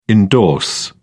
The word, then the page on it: indorse: British English